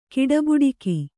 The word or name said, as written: ಗಡೆಬಡಿಗ